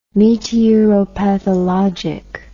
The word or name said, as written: meteoropathologic